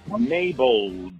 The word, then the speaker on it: MXBEL: The name of that new track is called Mabel, but it's spelled M-X-B-E-L.